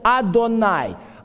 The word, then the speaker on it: Адонай: Иудеи никогда не произносят это имя при чтении. Они заменяют, когда они видят вот эти четыре буквы в тексте, еврейском тексте Торы. Они заменяют это слово, произносят «Адонай».